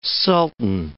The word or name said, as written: sultan